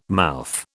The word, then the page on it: mouth: British English